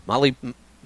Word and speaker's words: molybdenum: Molybdenum. Molybdenum. I think is the actual way you pronounce it.